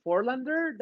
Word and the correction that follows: Fort Lauderdale: I don't know, how do you pronounce Fort Lauderdale?